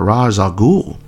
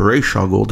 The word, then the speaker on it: Ra's al Ghul: Damian, while fighting all of them, finds his grandfather, Ra's al Ghul. Or Ra's al Ghul, depending on which TV show you're watching in pronunciation.